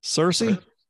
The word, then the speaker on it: Searcy: So, to all of our listeners out there, Gannon just moved into Harding University in Searcy or Searcy? What do you call it? – First one. – Searcy? – Yeah. – Searcy? Not Searcy? – Yes.